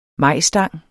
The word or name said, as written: majstang